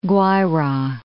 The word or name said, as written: Guaira